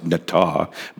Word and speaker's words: natah: And the Hebrew word there, there's a Hebrew word in verse one that it's pronounced natah, but it's basically translated as inclined.